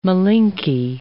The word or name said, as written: Malinke